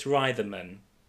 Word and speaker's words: Reitherman: But is it Reitherman? – It's Reitherman. – That just makes me think of a writhing man. – Is it an A? It might be Reitherman.